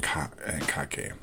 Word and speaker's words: kake: It's kumikata, kazushi, sukuri, and kake. You spell kumikata K-U-M-I-K-A-T-A. Kazushi is spelled K-U-Z-I-S-H-I. Sukuri is spelled T-S-U-K-U-R-I. And kake is spelled K-A-K-E.